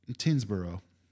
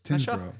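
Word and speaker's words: Tyngsborough: Tinsborough. – Actually, yes. – Tinsborough.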